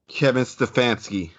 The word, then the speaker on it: Kevin Stefanski: Kevin Stefanski.